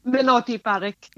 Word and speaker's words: Minoti Parikh: Minoti Parikh, yeah.